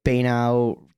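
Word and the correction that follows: Banaue: the rice terraces. – Oh, Banawe, yeah.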